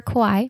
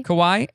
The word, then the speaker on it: Kauai: Over Kauai. – Kauai.